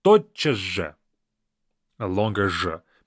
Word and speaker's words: тотчас же: First of all, the pronunciation is very interesting. Even though it's spelled as тотчас же, we kind of read it as тотчас же. We don't say тотчас же.